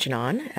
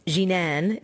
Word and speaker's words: Janan: My name is Janan. That is an Arabic name. It's actually mispronounced Arabic because I'm half white. So Jinan is how you would say it with an Arabic accent.